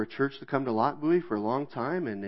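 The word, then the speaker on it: Lochbuie: It is pronounced Lock Bowie. It's kind of strange.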